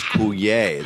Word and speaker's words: Coulier: And his name is Coulier.